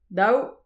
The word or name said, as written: daou